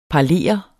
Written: parlere

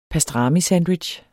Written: pastramisandwich

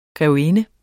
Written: grevinde

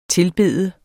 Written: TILBEDES

